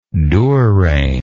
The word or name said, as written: durain